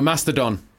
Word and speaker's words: Mastodon: Mastodon. – Can I say it like that? – No, Mastodon. – Mastodon. – No, it's very important you get this band's name right.